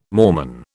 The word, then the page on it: Mormon: British English